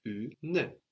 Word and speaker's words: une: And this E is also showing us now that we pronounce that N. The N is not on the end of the syllable anymore. We have two syllables now, une. Even though we don't generally pronounce that last E on the end, that's still like part of a separate syllable. So the U is just like in tu. And then we just get une in most dialects of French.